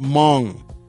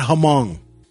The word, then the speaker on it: Hmong: And it's pronounced Hmong, not Hmong. Actually, it's Hmong. Hmong, y'all say it right.